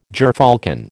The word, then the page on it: gyrfalcon: American English